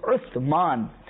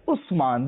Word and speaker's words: Uthman: In English it is also spelled with U-S, it is also spelled with U-T-H, but the correct pronunciation is Uthman. But people usually say it as Usman.